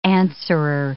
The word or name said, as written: answerer